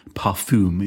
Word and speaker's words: parfum: And people who are interested in parfum, is that how you say it, Carol? – exactly that.